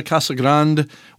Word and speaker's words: Casa Grande: And we're also headed to Pensacola and Jacksonville, Florida, and Sierra Vista, Casa Grande, which is how they say it down there, believe it or not, and Phoenix, Arizona.